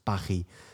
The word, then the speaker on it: Paris: 他法文常常写一些字然后不发音，比如说巴黎的法文是巴黑，那你说不是念 Paris 吗？那 Paris 是是英美人会讲的，可是对法国来说是巴黑。你为什么你为什么要写 S 文不发音的话？